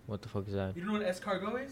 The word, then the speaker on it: escargot: Yeah, escargot. That's how you say it.